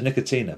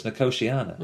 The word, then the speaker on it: Nicotiana: Yeah, sometimes it's Nicotina, but it's Nicotiana.